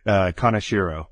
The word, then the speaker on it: Kaneshiro: Kaneshiro. So Ian Kaneshiro.